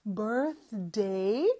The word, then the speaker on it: birthday: 그리고 birthday의 th 발음에서 d로 넘어가는 거 birthday 그거 많이 연습해 주시고요.